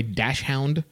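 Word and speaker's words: Dachshund: Is that how you pronounce that word, by the way, dash hound? – Uh-huh. Yeah, dash hound. Is that the right way? – Yeah.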